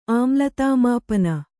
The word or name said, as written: ಆಮ್ಲತಾಮಾಪನ